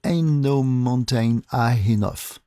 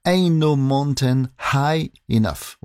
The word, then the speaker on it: Ain't No Mountain High Enough: Voici Marvin Gaye et Tammy Terrell avec Ain't No Mountain High Enough. Ce qui veut dire, si je le prononce mieux, c'est mieux. Alors je recommence. Ain't No Mountain High Enough.